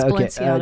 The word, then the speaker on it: Balenciaga: And how do you pronounce that brand name? – Which article is it from? – Balenciaga. Is that how it's pronounced? – Yes, it's Balenciaga.